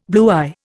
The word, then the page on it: bluey: Indian